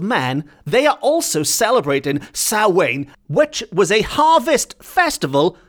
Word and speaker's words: Samhain: which is spelt Samhain, and if I'm pronouncing it correctly, it's Samhain, but it's spelled S-A-M-H-A-I-N.